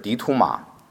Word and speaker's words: Didymus: 他名字的希腊语是 Didymus，也是 Didymus，这是 Didymus，他意思是双生子或是双胞胎，发音则是迪图马。